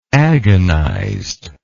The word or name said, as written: agonized